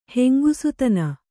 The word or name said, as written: ಹೆಂಗುಸುತನ